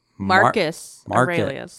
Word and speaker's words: Marcus Aurelius: Marcus. Like Mark. Marcus Aurelius.